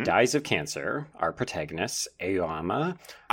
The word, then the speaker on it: Aoyama: But when I was reading the book, I was saying Aoyama. Like, it's like you're pronouncing the A-O. Aoyama.